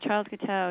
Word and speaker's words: Charles Guiteau: Can you tell us about, is it Charles Guiteau? Is that how you say his name? That's right, Charles Guiteau, yeah.